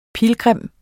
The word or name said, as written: pilgrim